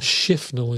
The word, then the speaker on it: Shifnal: Shifnal is that's pronounced.